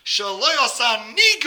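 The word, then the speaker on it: shelo asani: By saying a bracha she'loi asa, and you have to put the emphasis on the ni. She'loi asa ni goy.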